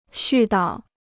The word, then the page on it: 絮叨: xù dao